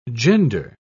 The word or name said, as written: gender